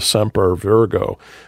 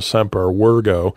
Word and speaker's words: Semper Virgo: But the point is not to get hung up on what's called the Semper Virgo, or if you were taught barbaric pronunciation, the Semper Virgo, that Mary was always virgin.